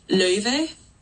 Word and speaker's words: Löve: But, you know, it's two vowels that are just don't exist in English. So, like, in true, like, clean Icelandic, it's pronounced Löve.